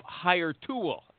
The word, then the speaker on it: Hirtual: Okay, after I thanked you, now I want to thank our sponsors, Martin Snyder of PC Recruiter and Nin Tran with that silent H of Hire Tool, HIRTUAL. You know what? I got to spell the sponsor's name every time I say it, okay?